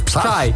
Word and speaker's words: Psy: Psy.